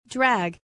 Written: drag

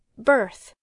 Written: birth

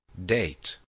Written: date

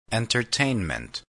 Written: entertainment

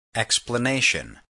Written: explanation

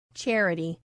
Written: charity

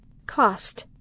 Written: cost

